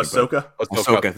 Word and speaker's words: Ahsoka: Because I know they, that didn't work with Ahsoka, but, or however you say her name, excuse me. – Ahsoka, thank you.